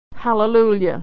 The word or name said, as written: hallelujah